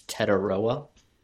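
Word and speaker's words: Tetairoa: And by the way, Carr, it's Tetaroa for reference.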